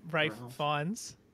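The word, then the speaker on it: Ralph Fiennes: Rafe Fiennes. – Rafe. I always say Ralph. It's got an L in it. You should pronounce it.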